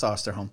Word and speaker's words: Osterholm: I don't know how to say it. – I think it's Osterholm.